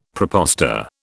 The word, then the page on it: prepostor: British English